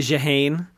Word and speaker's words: Jehan: So I guess first I want to ask you about your monodrama, The Passion of Jehan, Joan of Arc. I'm not sure if I'm pronouncing that right, but we say Jehan, which is a French variation of how she would sign her name.